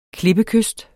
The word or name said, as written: klippekyst